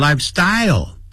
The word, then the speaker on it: lifestyle: 好， lifestyle 不要念成 lifestyle， lifestyle 重音在第一个音节， style 因为有 L 结尾，所以舌尖要抵上来，像发出欧洲的 O 的音 style。